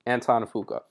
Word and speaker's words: Antoine Fuqua: Um, I believe it's Anton Fuqua. – Okay, thank you. Anton Fuqua. – Is how you pronounce it, yeah.